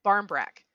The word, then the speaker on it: barmbrack: And I don't know if either of you have ever heard of Brambrack. no. I think I'm saying that right. – No. – Uh, if anybody speaks, what is it, Celtic or Gaelic? Correct me. Barmbrack. It's barmbrack.